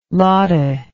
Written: lotta

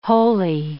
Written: holey

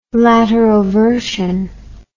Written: lateroversion

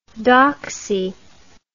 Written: dachsie

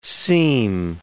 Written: seem

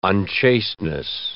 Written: unchasteness

